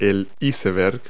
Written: iceberg

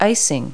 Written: icing

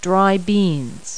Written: dry bean

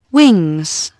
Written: wings

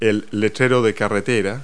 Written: road sign

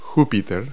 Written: jupiter

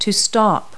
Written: stop

